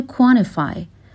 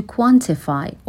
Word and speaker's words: quantify: And difficult to quantify or quantify. این رو قبلا هم داشتیم که اگر T بین N و یک حرف صدادار قرار بگیره توی تلفظ آمریکایی میتونیم T رو نخونیم.